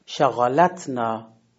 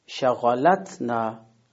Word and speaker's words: شغلتنا: پس تکیه صوتی به حرف قبل از ساکن منتقل میشه، میشه حرف لام. پس نحوه تلفظ صحیح این کلمه میشه شغلتنا.